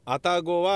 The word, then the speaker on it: 愛宕: では愛宕神社と言われている愛宕とは何なのか。え皆さん愛宕という字書けますか愛宕は、え 2 文字で愛宕と読むので、えー、愛という字恋愛の愛ね。